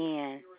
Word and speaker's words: Anne: P-E-N-D-E-R-G-A-S-T. Pendergast. – Thank you. Can I get the first name? – Mm-hmm. Anne. A-N-N-E.